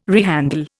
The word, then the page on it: rehandle: Indian